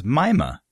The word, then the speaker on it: Mima: I read a phonetic spelling of it, and they're pronouncing it as Mima.